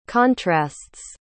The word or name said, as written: contrasts